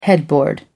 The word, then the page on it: headboard: American English